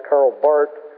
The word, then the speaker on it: Karl Barth: B-A-R-T-H. In German, you don't pronounce the H. It's not a Z sound, so it's usually pronounced Karl Barth.